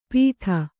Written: ಪಿಟ

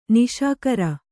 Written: ನಿಶಿಕರ

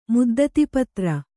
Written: ಮುದ್ದತಿಪತ್ರ

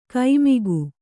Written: ಕೈಮಿಗು

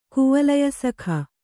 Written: ಕುವಲಯಸಖ

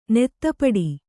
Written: ನೀತಿಪಾಠ

